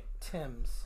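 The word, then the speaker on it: Thames: His last name is like the river in England. – Right. – Timms. That's how you pronounce it. Or it could be Thims.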